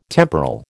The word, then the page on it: temporal: American English